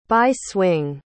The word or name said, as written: bi-swing